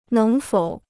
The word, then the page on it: 能否: néng fǒu